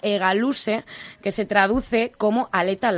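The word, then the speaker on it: hegaluze: Se escribe con H, H-E-G-A-L-U-Z-E, e, egaluse, y significa aleta larga.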